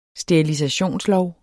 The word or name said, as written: sterilisationslov